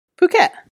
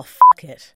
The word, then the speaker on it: Phuket: It's not pronounced like that. God. – Is it not? How is it pronounced? – Phuket. – Oh, fuck it. – Definitely not pronounced about that.